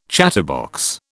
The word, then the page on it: chatterbox: British English